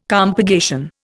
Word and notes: compurgation: Indian

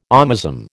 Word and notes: omasum: American English

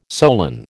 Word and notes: solan: American English

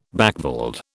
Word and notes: backveld: British English

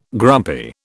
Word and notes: grumpy: British English

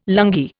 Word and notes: lungi: Indian

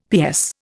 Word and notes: PS: Indian

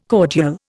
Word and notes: cordial: Indian

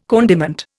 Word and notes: condiment: Indian

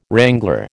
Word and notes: wrangler: American English

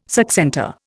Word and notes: succentor: Indian

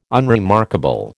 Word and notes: unremarkable: American English

